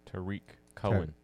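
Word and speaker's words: Tarik Cohen: Running back Tariq Cohen. Did I say it right? – Tariq Cohen.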